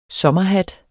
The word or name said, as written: sommerhat